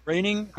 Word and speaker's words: Groening: I never have known how do you pronounce the name of the guy that created the Simpsons? Is it Groening? – Groening.